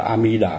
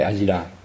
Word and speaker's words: A-mi-đà: Chữ cái tên của Phật A-mi-đà là đúng là A-mi-đà chứ không phải A-di-đà.